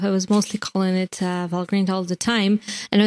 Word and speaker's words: Valgrind: So it is Valgrind. And not Valgrind, as many people do say.